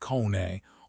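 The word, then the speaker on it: Koné: Scouts are now ignoring the highlights and hunting for players like Manu Kone, pronounced Kone, or Ibrahim Embe, pronounced Baye, because they offer maturity and tactical utility.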